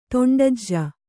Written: ದುಂಡಿಜೆ

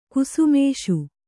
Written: ಗುಸುಮುಸಿ

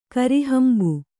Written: ಕರಿಹಂಬು

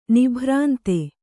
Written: ನಿಭ್ರಾಂತಿ

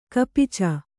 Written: ಕಪಿಚ

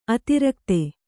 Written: ಅತಿರಾಗತೆ

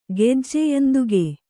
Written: ಗೆಜ್ಜೆಯಂದುಗೆ

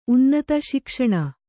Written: ಸುಖಾಸನ

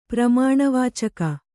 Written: ಪರಿಮಾಣವಾಚಕ

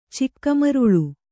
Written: ಚಿಕ್ಕಮರುಳು

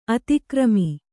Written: ಅತಿಕರ್ಮ

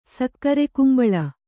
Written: ಸಕ್ಕರೆಕುಂಬಳ

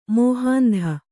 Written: ಮಹಂತೆ

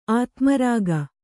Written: ಅಧೋಮಾರ್ಗ

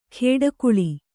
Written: ಖೇಡಾಕುಳಿ

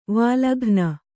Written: ವಲ್ಗನೆ

